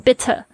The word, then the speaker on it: bitter: 好，那这边呢我有讲到一个 bittersweet，这个 bitter 这个也会有人念成 bitter 但是就是一个英式发音跟美式发音的差别。那这个 TT 呢，它介在两个 vowel，就是母音或者你可以说它是元音之间呢，